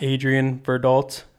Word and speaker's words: Adrian Verdult: You can find me on Instagram at Adrian Verdult.